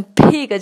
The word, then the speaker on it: big: 所以这个 b 它不再是那种非常强烈的 b 的浊辅音，它甚至往 p 这个清辅音上面去转化，会唱成 Gonna take a for a ride on a big big，它已经不再是 big 了，都会念成 pig 这样的感觉，big jet plane。